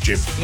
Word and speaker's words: GIF: And so will the guy who invented them, who insists that it is pronounced Jif. – Yeah. – Now, people are flying in and come back, it's a GIF, Nige.